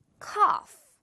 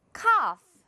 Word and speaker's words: cough: cough. 기침하다 또는 기침. 자, 갑니다. 바로 cough. 한 번 더. cough. Very good. GH를 이렇게 보고 바로 F 소리 낼 수 있다면요.